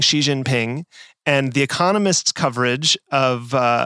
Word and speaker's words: Xi Jinping: It all depends on, of course, how you romanize a Chinese character, because what we're talking about is how we pronounce a Chinese character. But Xi Jinping is the right way to go.